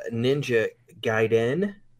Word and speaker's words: Ninja Gaiden: So the game that I chose was uh, Ninja Gaiden is how you pronounce it.